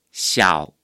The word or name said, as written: 小